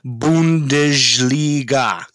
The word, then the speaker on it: Bundesliga: The Bundesliga, I believe it's pronounced. – Yeah, it's pronounced Bundesliga.